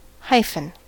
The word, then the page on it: hyphen: American English